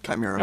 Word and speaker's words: Chimera: How do you pronounce it? – Chimera. – Okay, good.